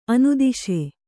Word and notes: ಅನುದಾಸ: anudāsa

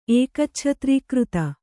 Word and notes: ಏಕಚ್ಛತ್ರೀಕೃತ: Kannada

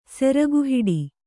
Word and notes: ಸೆರಗುಹಿಡಿ: Kannada

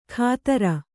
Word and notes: ಖಾತರ: Kannada

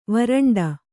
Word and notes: ವರಾಂಡ: varāṇḍa

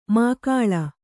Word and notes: ಮಾಕಾಳ: mākāḷa

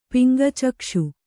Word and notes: ಪಿಂಗಚಕ್ಷು: piŋga cakṣu

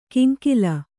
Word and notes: ಕಿಂಕಿಲ: Kannada

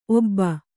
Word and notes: ಓಬ: ōba

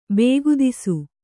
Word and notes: ಬಕ್ಕದಾಸ: kannada

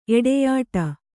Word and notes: ಎಡೆಯಾಡು: eḍeyāḍu

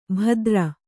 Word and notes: ಭದ್ರ: bhadra

